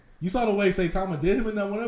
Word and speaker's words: Saitama: Saitama.